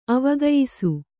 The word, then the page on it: ಅವಗಯಿಸು: kannada; avagayisu